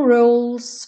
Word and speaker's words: rules: No right, no wrong, no rules for me. Lembra de rules, não pronunciar o E. Então, rule Chegou no L, já vai pro S.